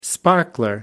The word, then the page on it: sparkler: American English